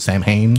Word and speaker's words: Samhain: And so, and that's how you that's how you pronounce it, Samhain.